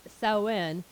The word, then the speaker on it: Samhain: The Samhain, which is actually pronounced, because it's Celtic, is pronounced Samhain.